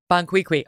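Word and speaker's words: Bon Qui Qui: Bonquiqui.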